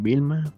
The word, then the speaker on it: Velma: Belma. que se pronuncia Vilma, pero pues, Belma, Vilma.